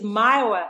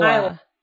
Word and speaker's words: Myoa: Yeah, I'm so happy to be here and, uh, nothing against you because, but it's Myoa. I guess it's Myoa, like Iowa from um, my original name, Mayowa.